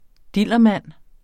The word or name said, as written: dillermand